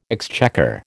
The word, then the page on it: exchequer: American English